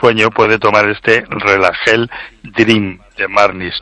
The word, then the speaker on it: Dream: Esta es la palabra inglesa que se pronuncia Dream, entonces el producto se llama Relagel Dream de Marnis.